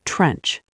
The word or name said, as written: trench